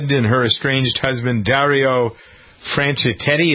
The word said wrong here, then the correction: Franchitti: is that how you pronounce his name? – Franchitetti, isn't it? – Franchitetti. Where's Roberta? – Franchitti. – How do you pronounce it? – Franchitti. – Franchitti.